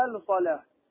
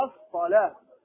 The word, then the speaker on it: الصلاة: الصلاة، نطق اللام حي على الصلاة، هو حي على الصلاة، اللام هنا شمسية لا تنطق مفهوم، وهذا لسبب العجمة فقط، وإلا هذه ما يخطأ فيها عوام العرب فضلا عن طلاب العلم.